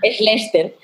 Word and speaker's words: Schlechter: Así que mi nombre es Camila Baraya y hoy tengo como invitada en nuestro episodio 11 a Irene Schletzer, ¿Schlechter? ¿Cómo se pronuncia bien tu apellido? Se pronuncia Schlechter. Schlechter fundadora de The Bonding, una startup que anunció eh, su exit la semana pasada.